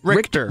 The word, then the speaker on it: Richter: Question number four, actor Jason James Rector was arrested last week for allegedly kicking in a door and grabbing his girlfriend in an argument. At age 11, Rector, am I saying that right? – Richter.